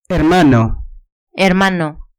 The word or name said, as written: hermano